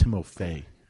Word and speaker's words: Timofey: But, yeah, I think it's pronounced Timofey. – Timofey Mozgov. Yes, you're probably right.